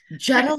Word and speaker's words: Geta: Geta like the car.